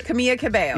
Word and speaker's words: Camila Cabello: Julie Bowen, Jessica Biel, and Camila Cabello. – I think you said it right recently. Camila Cabello. I think you say it right. – Camila Cabello.